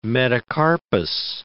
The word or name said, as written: metacarpus